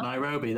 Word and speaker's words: Nairobi: Nairobi, there we go.